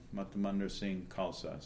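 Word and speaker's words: Matamandir Singh Khalsa: If you type in my name, Matamandar Singh Khalsa, you get a bunch of Spotify. – Okay, you better spell that. – Okay, M-A-T-A-M-A-N-D-I-R, Matamandar Singh, S-I-N-G-H, means lion, Khalsa, K-H-A-L-S-A, means purity. Matamandar Singh Khalsa.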